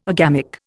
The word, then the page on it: agamic: Indian